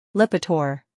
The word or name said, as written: Lipitor